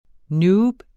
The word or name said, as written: noob